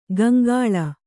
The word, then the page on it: ಗಂಗಾಳ: Gaŋgāḷa